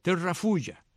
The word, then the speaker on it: Terrafugia: Ok, Terrafulla. ¿Quién es Terrafulla? Terrafulla, que se escribe Terrafugia, con WR y FUGIA. Terrafugia.